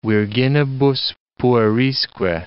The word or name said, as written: virginibus puerisque